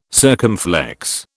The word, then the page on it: circumflex: British English